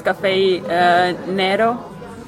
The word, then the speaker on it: Nero: It should be pronounced Cafe Nero. Nero is black in Italian.